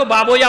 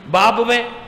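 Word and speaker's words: بابویہ: لوگ اس کا تلفظ صحیح نہیں کر پاتے. بابوے کو بابویا پڑھتے ہیں، کیا پڑھتے ہیں؟ بابویا، سیبویا، قولویا، یہی ہیں. بابوے، قولوے، سیبوے.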